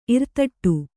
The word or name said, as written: ಇರ್ತಡಿ